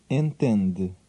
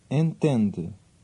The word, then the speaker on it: Entende: Entende. – Listen and repeat, part by part, understand. – Entende. De. Tende. Entende.